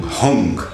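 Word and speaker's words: Hang: Hung.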